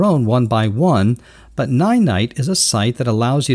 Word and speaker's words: Ninite: It's called Ninite. That's spelled N-I-N-I-T-E.